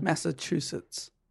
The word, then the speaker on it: Massachusetts: Massachusetts. – It is a tough one. – Massachusetts. – That's right. That's what I said. Austin, Massachusetts. Yeah, you know where that's from? Yeah, that's right. Austin, Massachusetts.